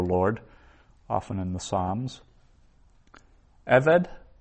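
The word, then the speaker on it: Eved (עֶבֶד): Now, notice that means servant, and it's got two sigils, so two short e, sounds eved, and that class of nouns, the accent's always on the first syllable. Eved, whereas usually the accent's on the last syllable, but there it's Eved.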